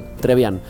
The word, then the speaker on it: Trebian: Hay que pronunciar, no hay que hacer ni un quilombo con la R, por ejemplo. Trevian.